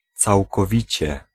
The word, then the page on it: całkowicie: IPA: [t͡s̪awkɔˈvʲit͡ɕɛ]